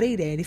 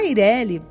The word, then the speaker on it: EIRELI: Se você pronunciar a letra E como letra E, como nós cariocas fazemos, você vai ter a tendência em pronunciar Eireli. Fique à vontade para escolher aquela que você se sente mais à vontade, não há uma regra é, específica para essa pronúncia.